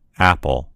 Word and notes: apple: American English

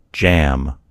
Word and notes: jam: American English

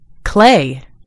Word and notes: clay: American English

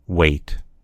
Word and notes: weight: American English

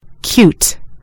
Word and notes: cute: American English

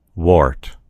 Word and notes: wart: American English

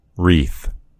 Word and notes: wreath: American English

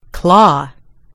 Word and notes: claw: British English

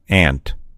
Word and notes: ant: American English

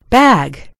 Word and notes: bag: American English

